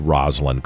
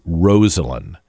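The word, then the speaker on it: Rosalynn: Rosalind Carter, not Rosalind Carter. I think I've said it wrong for all these years.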